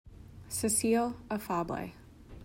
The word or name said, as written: Cecile Afable